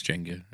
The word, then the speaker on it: Jenga: Interesting fact, I pronounced it Jenga until the age of about 21 when somebody corrected me and said it's Jenga, and I didn't believe them until...